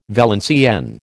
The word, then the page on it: Valenciennes: American English